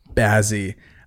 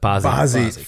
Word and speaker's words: Bazzi: Lil Nas X, Jack Harlow, Bazzi. – Not how you say his name, but Bazzi.